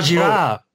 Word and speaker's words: Girard: Oh, Girard. Rene Girard. – How do you spell his name? – G-I-R-A-R-D. – Okay. – René Girard. – Yeah, so René Girard.